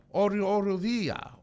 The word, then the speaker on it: arrodilla: Arrodilla.